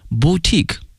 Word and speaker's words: boutique: 今天呢我们用这个词表示充满的民间艺术的工作室哈、啊、叫 it studded， studded 哈、啊、是一个被动语态， studded with folk art workshop， studded with folk art workshop galleries 啊画廊啊 galleries museums 博物馆 and boutique hotels boutique，看这个词精品哈 b-o-u-t-i-q-u-e 哈，它的发音是 boutique，